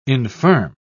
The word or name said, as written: infirm